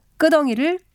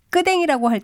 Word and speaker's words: 끄덩이: 발음을 편하게 하려고 끄덩이를 끄댕이라고 할 때가 있지만 이는 표준 발음으로 인정되지 않는 것입니다.